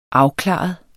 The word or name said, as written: afklaret